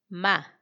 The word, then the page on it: ma: Macuiltianguis